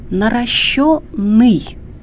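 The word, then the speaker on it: наращённый: Во-вторых, гласный «ё», который, как известно, в современном русском языке всегда является ударным. Итак, запомните, пожалуйста, «наращенный» или «наращенные» и так далее.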